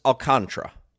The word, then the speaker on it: Alcantara: Yeah, Sandy Alcantara, whatever, is pitching for the Alcantara. – Marlins tonight. Yeah. – He prefers that it's pronounced Alcantara.